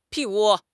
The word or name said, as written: 屁股